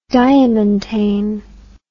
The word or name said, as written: diamantane